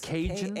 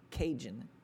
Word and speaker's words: Kajun: It's Cajun? K-A-J-U-N. Cajun.